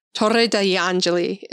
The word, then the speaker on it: Torre degli Angeli: Torre degli Angeli is how you say it in Italian.